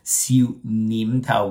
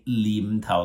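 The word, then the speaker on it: Siu Nim Tao: For example, Xiu Nim Tao, we spell with an N, but many Cantonese pronunciation, they will just say Lim Tao, like they have a lazy way instead of saying it with an N, especially those from Hong Kong.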